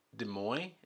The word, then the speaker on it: Des Moines: Des Moines? That's how you pronounce that? – Yeah.